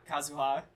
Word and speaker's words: Kazuha: Yeah, Kazuha.